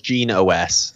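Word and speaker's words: GenOS: Yeah, I believe it's Gene OS. So it has EOS in the name, but they pronounce it Gene OS, like operating system.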